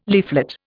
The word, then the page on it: leaflet: Indian